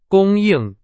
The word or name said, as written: 供应